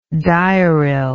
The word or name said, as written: diuril